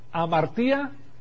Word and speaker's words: hamartía: Hamartía, y se pronuncia hamartía, como jamón, hamartía, tiene que ver con el pecado, es la doctrina del pecado.